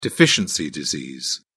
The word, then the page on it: deficiency disease: British English